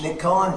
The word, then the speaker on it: Nick Cullen: Nick Cullen, Director of Kern County Animal Services, NICKCULLEN.